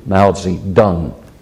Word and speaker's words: Mao Zedong: That's how it can be pronounced, and that's my preferred pronunciation, Mao Zedong.